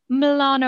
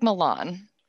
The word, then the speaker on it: Milan: Apparently, no offense to our friends in Ohio, but you don't pronounce things the way we think they should be pronounced. Like Lima is Lima. Apparently, Milan is Milan.